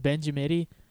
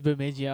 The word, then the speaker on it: Bemidji: I always pronounce it Benjamidi. – Benjamidi. That's how you pronounce it when you first found out. – I was like, hey, Benjamidi. I was like, Benjamidi, Minnesota. He's like, it's Bemidji.